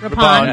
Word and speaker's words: Rippon: How do you pronounce Rippon. – It? Rippon.